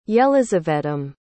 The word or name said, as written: yelizavetam